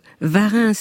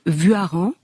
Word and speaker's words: Warens: Oui, je veux vous corriger parce que Varins, c'est justement la prononciation éducation nationale, et Rousseau a toujours dit Vuaran, parce que ENS, ça se prononce en Savoie, et parce que le véritable nom de famille, c'est V-U-A-R-E-N-S, qu'elle est la graphie originelle.